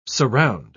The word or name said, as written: surround